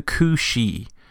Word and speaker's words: Cusith: Our first animal is the Kushi. Now here that's spelled C-O-O-S-H-E-E, which I think is an older kind of anglicization of the spelling. You're more commonly going to find this spelled C-U-S-I-T-H.